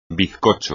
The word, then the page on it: bizcocho: espagnol